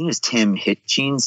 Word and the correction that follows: Hutchings: Is that how you say his name? The TV commentator. Tim Hutchings.